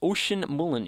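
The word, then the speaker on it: Oisin Mullin: I've found out how he's pronounced. Oisin Mulland is how he's pronounced, making his debut.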